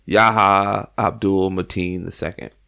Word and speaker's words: Yahya Abdul-Mateen II: Yahya Abdul-Mateen II, who we know as Manta in Aquaman.